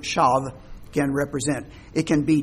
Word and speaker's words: shav: If you want to anglicize it, you could spell it S-H-A-V, shav.